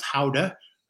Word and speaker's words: Gouda: But the fun thing is, after we did the windmill, we went over to a Gouda, which they pronounce Houda, cheese factory, and a farm.